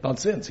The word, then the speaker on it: Vincennes: Yes, it took me like 3 weeks before I visited to pronounce Vincennes correctly. So say it again. Vincennes.